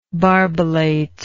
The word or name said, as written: barbellate